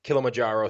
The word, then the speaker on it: Kilimanjaro: Mine is the Kilimanjaro Safari.